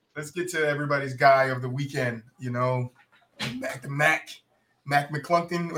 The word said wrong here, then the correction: McClung: That's how you say his name? – Mac McClung, return of the Mac.